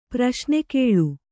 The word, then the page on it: ಪ್ರಶ್ನೆಕೇಳು: Kannada